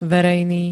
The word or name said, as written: verejný